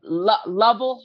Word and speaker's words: Lovell: How did you make the decision for Lavelle to direct? And I hope that's Lavelle. It's Lavelle. – Lovell Holder. – Lovell. – The emphasis is on his first syllable of his name.